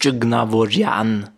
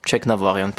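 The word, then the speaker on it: Tjeknavorian: Aber original in der armenischen Sprache ist es erstens das E gibt's nicht, Check gibt's nicht, das ist Cieknavorian. Da ist es tatsächlich am Ende die Betonung, aber Cieknavorian passt.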